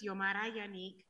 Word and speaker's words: Xiomara Yannick: So for 1773, I would like to call forward, and I'm going to destroy your pronunciation of your name, but Zamora Yannick, was that even close? – Yes.